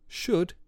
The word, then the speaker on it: should: Should and walk don't have L sounds in them.